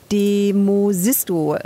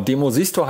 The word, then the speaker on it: Demosisto: Und der hat dann auch die Hongkonger Partei Demosisto gegründet, wenn ich es richtig ausgesprochen habe. Was ist eigentlich aus dem geworden? Was macht der jetzt? – Genau, Demosisto heißen die.